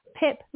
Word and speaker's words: Pip: so G-W-Y-N-N Pip like in an apple um,